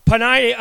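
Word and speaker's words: Peninnah: And look, April's going to dispute with me that it's pronounced that way. I, I had it. I looked it up. It's Panini. Uh, anyway, where was I? Panini uh, had children, but Hannah had no children.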